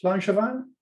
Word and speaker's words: Langevin: And am I saying that right? Langevin? – I think you're saying it how I say it. I guess it's probably now you've brought it up. Presumably French, Langevin.